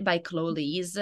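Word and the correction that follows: Liese: I think I'm pronouncing her name right. If I'm not, I'm so sorry. But it's, do you know how to pronounce it? – Chloe Lisa. – Lisa. – Yep. – Okay. Oh, I was really off with that.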